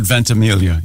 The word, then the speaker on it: Ventimiglia: Milo, is it Ventimiglia? – Does he go with the soft G or does he do the Mike Birbiglia thing? – I have heard the soft G. – I've heard Ventimiglia, yes.